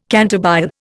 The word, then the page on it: cantabile: Indian